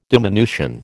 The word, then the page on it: diminution: American English